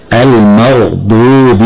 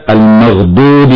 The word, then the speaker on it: المغضوب: من الخطأ تقيق الضاد في كلمة المغضوب حتى تخرج دالا، فبعضهم يقرأ خطأ هكذا: المغضوب عليهم، وأنه لا يبالغ في التفخيم بالنسبة للضاد فيقول المغضوب عليهم.